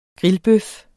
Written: grillbøf

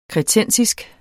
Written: kretensisk

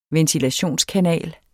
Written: ventilationskanal

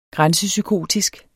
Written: grænsepsykotisk

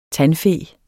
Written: tandfe

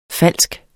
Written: falsk

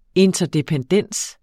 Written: interdependens

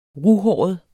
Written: ruhåret

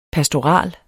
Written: pastorale

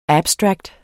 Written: abstract

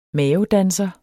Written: mavedanser

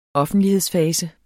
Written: offentlighedsfase

